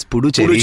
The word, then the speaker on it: Puducherry: It's Puducherry.